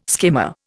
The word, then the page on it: skimmer: Indian